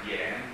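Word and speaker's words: dien (点): non è dian, è dien, I-A-N si pronuncia I-E-N e U-I si pronuncia Wei.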